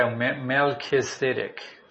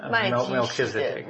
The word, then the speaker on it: Melchizedek: Yeah, in the book of Hebrews, the writer actually tells in detail about how Jesus Christ is a priest in the order of Melchizedek. – Yeah. 在希伯来书里面呢，有很详细的说明哈。看一下希伯来书的第五章的六跟七节。 How to say it? – In English, we say Melchizedek. – Melchizedek.